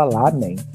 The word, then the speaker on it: ramen: O, o R lá tem som de L, por isso que, tipo, se escreve ramen, mas fala lamen.